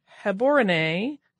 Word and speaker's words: Gaborone: but it's a B-A-T, not a B-O-T. Also, the capital city of Gaborone is pronounced more like Heborone with a guttural G and the accent on the final E. So, I'm guessing Gaborone, and I may be still butchering that, but I'm trying my hardest.